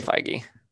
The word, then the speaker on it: Feige: Even like Kevin Feige, I think, right? That's how you pronounce his last name. – I say Feige.